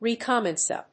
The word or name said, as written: recommence